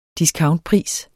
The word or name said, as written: discountpris